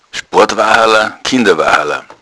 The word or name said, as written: sport